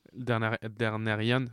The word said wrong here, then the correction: Targaryen: j'arrive pas à le prononcer. – Targaryen.